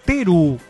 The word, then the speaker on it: Teru: Pensando especialmente en los niños, la mascota se llama Teru, termina con U, con acento en la E.